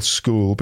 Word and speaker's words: school: Yeah, it's an interesting word as well, school, because there's not many words that have C-H. – C-H that are pronounced K. – Like a K, yeah.